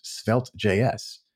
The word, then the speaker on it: Svelte: And he's also one of the co-authors, should I say, or author of one of my favorite frameworks out there, which is Svelte.js.